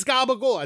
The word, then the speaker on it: gabagool: And it was like, why'd you ask me to get gabagool if you want a Capicola? Because it's gabagool. That's how you say it.